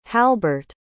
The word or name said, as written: halburt